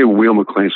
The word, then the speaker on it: Weil-McClain: Is it Weil-McClain or Weil-McClain? – I say Weil-McClain. So if I've been saying it wrong, I've been saying it wrong for a while. – As I recall from my musical training, I think in German, the second syllable is the one that says its name. So I guess it would be Weil-McClain, like if it was W-I-E-L, it'd be wheel.